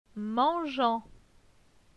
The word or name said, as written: Mangeant